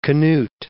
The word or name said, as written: Cnut